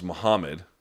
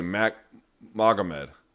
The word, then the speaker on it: Magomed: For example, the name Magomed is pronounced Muhammad, and you could hear Magomed Ankalaev's corners pronounce it like Muhammad very prominently while yelling advice during his fight last Saturday. You know, all the commentators say Magomed.